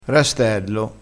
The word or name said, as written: rastello